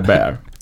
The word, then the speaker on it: Bear: för att Babs alltid sa Polar Beer istället för Bear.